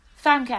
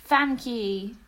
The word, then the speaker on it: Famke: Famke Janssen. – There we go. – I think it's Famke because she's Dutch, isn't she? – Ah, cool, cool.